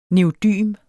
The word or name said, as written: neodym